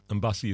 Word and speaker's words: Mbassi: Mbassi is spelled M-B-A-S-S-I. Coco Mbassi comes from a brand new album from her called Ewekedi.